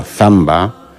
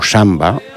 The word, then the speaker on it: zamba: Estamos oyendo la zamba o samba, puesto que la Z somos muy pocos los hablantes españoles que la pronunciamos, más bien casi todos dicen samba, pero que hay que distinguir clarísimamente de la samba brasileira.